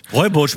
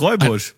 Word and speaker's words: Rooibos: Rooibos? Habe ich noch nie gehört. – Rooibosch, Mann. – Ach so, schreibt man. So schreibt man doch nicht Rooibosch. – Doch, so schreibt man Rooibosch. Das ist Rooibosch.